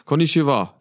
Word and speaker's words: Konnichiwa: Konnichiwa. – Ja, genau. Konnichiwa. Konnichiwa. Konnichiwa. Konnichiwa. Konnichiwa. Ich grüße euch. – Konnichiwa, ihr beiden, und an alle Zuhörer auch. Konnichiwa.